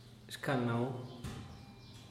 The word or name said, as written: xhcalnau